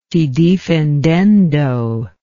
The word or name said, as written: se defendendo